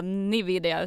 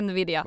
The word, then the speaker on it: Nvidia: Men vad som mer har hänt i veckan som nog absolut ingen börsintresserad har missat, det är ju att Nvidia... Förlåt, jag får inte säga Nvidia, utan det ska vara Nvidia.